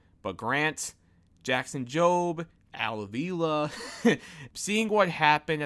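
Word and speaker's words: Jackson Jobe: And I feel like the draft pick of Jackson Job, if I'm saying that correctly. – Jackson Job, right.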